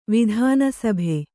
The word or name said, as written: ವಿಧಾನಸಭೆ